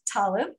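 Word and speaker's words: Talev: It's rhymes with olive, Talov, and it's T like Tom, A-L-E-V like Victor.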